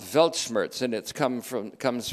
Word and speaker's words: Weltschmerz: It's Weltschmerz. Weltschmerz, it's how it's pronounced. There are W's or V's, of course. Weltschmerz.